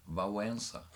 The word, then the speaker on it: Wałęsa: Det stavas Valesa, men det uttalas ju Wałęsa. – Ja, det uttalas som engelskans W när man säger what.